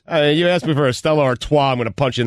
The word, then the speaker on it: Stella Artois: Stella Artois.